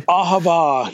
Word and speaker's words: Ahava: I think the last one that you have listed here is Ahava. Am I pronouncing that correctly? – Ahava, yeah. It's another Hebrew word.